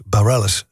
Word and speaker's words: Bareilles: was van Sarah Bareilles, 2008 menig discjockey. Had even problemen met de achternaam, omdat hij niet goed wist hoe ik moest uitspreken. Allerlei varianten had je erop, maar het was Bareilles.